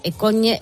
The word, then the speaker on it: Cogne: Para que nuestros ponedores se sitúen, Cogne es, que lo has pronunciado muy bien, es un pueblo paradisíaco en el norte de Italia, en plenas montañas, como en un valle de montañas.